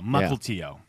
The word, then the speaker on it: Mukilteo: I think it's Mukilteo. – The tribe so the L is silent, or you said the L? – No, you say time. – The L. – I said the L. Mukilteo.